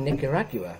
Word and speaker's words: Nicaragua: I'm in fucking Nicaragua, right? I pronounced that incorrectly coming into America, and the... – No, you pronounced it correctly, but they're idiots.